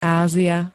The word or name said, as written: Ázia